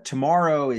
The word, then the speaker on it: TMRW: Tomorrow is capital TMRW. So it's a combination of Tiger Woods and Rory McIlroy's initials, but pronounced tomorrow, which is a cheeky way of taking out all the vowels and seeming, I guess, appealing to millennials and Gen Xers, Gen Zs.